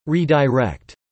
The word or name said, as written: redirect